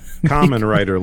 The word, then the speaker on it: Kamen Rider: Kamen Rider, like Ramen Rider, but with a K.